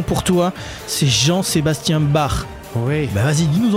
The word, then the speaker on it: Bach: Parce que oui, une bonne fois pour toutes, ça se prononce Bach, pas Bach.